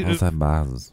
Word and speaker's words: Jose Baez: You know, I did say that I liked I can't think, what is it, Jose Baez? Did I get his name right? – Yeah. – for somebody, Jose Baez.